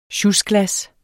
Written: sjusglas

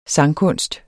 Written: sangkunst

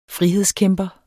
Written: frihedskæmper

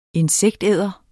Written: insektæder

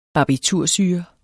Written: barbitursyre

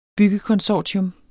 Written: byggekonsortium

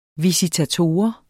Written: visitator

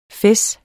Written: fez

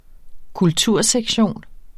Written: kultursektion

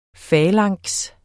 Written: falanks

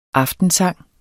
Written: aftensang

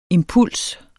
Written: impuls

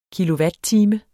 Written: kilowatttime

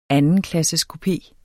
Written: andenklasseskupé

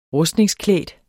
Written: rustningsklædt

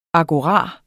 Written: AGORAEN

